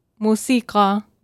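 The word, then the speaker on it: música (موسيقى): La palabra música en árabe sería música. – Música. Entonces no es palabra esdrújula en árabe. O sea, no, lleva el énfasis en la antepenúltima sílaba, sino en la penúltima.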